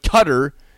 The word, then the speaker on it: Qatar: But Qatar, C-U-T-T-E-R for Q-A-T-A-R is how you pronounce it.